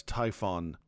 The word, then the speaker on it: Typhon: First off, the villain for last week's issue, I believe I correctly pronounced two episodes ago, as Typhon. Typhoon, as I have made myself aware, is spelled with two O's, T-Y-P-H-O-O-N. Our villain is T-Y-P-H-O-N, singular O.